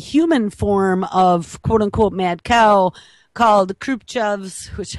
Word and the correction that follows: Creutzfeldt-Jakob: I never say it right, Creutzfeldt-Jakob's disease, or CJD for short.